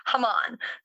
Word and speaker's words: Haman: So no, but that is the actual Hebrew pronunciation. It's Haman.